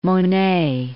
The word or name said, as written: Monet